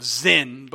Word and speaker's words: Sin: It's actually the wilderness of Zin, but we pronounce it sin in English.